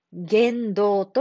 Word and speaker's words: 言動: こんな言動というのは、言動は、言う、動くと書きます。言動と読みます。